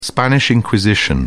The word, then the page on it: spanish inquisition: British English